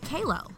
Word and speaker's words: Kalo: I had never heard it said until she heard me mispronounce her name and corrected me by saying, It's pronounced Kalo, like Halo.